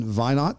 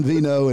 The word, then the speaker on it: Veinot: We've got Don Veinot. Did I say your name properly? – Veinot. – I wasn't even close. So, all right, Don Veinot and Marcia Montenegro.